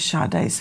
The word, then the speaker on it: Sade: Go to Justice for Sade, and that is spelled S-A-D-E. Like you would say Sade, like sage. It's pronounced Sade.